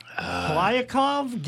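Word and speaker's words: Kliavkoff: Will George Klyakov get UNLV into the Pac-12? – I don't think you're far away. Kli is the first syllable, not Kly. But other than that, I think you got it.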